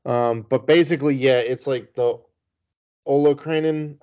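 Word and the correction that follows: olecranon: I think. Something like that. I probably just butchered it. – Olekrinen. That's what it was. – Olekrinen. There you go.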